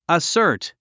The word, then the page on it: assert: əsə’ː r t; アッサート